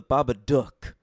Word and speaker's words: Babadook: And I prefer the proper pronunciation, The Babadook, because it's supposed to rhyme with fucking book in that movie.